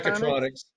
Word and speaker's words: Mechatronics: And you had mentioned, I might not say it right, Metatronics? – Metatronics. – Mechatronics.